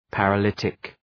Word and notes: paralytic: {,pærə’lıtık}

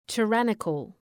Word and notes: tyrannical: tı’rænıkəl

